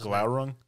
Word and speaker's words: Glaurung: With Gralong, or however you pronounce it. – Yeah, it was close. Who is the father of dragons? – Gralong. Gralong, or however you pronounce it. – Glaurung? – Yeah, that one.